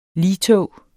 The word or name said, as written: ligtog